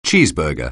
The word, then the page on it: cheeseburger: anglais